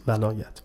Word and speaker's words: ولایت: خب کلمه ولایت، ولایت اینجا با فتح است، ولایت.